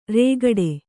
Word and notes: ರೇಗಟೆ: rēgaṭe

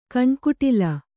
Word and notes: ಕಣ್ಕುಟಿಲ: kaṇkuṭila

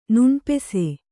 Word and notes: ನೆನಪಿಸು: kannada

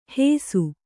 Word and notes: ಹೇಸು: hēsu

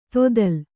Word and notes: ದತ್ತೆಲೆ: Kannada

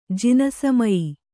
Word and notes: ಜಿನಸಮಯಿ: Kannada

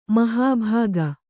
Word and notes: ಮಹಾಭಾಗ: mahābhāga

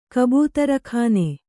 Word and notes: ಕಬೂತರಖಾನೆ: Kannada

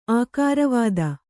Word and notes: ಆಕಾರವಾದ: ākāravāda

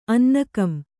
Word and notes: ಅನ್ನೆಗಂ: annegam

